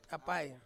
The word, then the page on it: apai: Ansus